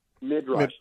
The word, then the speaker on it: Midrash: Is it, how do you spell it? Midrash? – Yeah, M-I-D-R-A-S-H. – Midrash. – Midrash. Uh-huh.